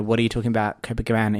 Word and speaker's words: Copacabana: It's the song Copacabana. Did I say it right? – Copacabana.